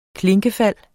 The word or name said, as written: KLINKEFALDENES